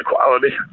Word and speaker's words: Kwalyti: Quality? – Yeah, Quality. It's kind of a play on words. – Right. It's spelled K-W-A-L-Y-T-I.